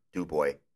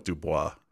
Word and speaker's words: Dubois: And they pronounce it Dubois. – Okay. Dubois. No S.